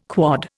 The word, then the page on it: quod: Indian